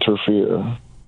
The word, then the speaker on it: Enterfear: It's called Interfere. It's crazy. We're just talking about fear. But it's one word, and it's spelled like E-N-T-E-R-F-E-A-R. – Oh, that's dope. – Interfere. I'm entering fear, and at the end of the album, I want to exit all those motherfuckers.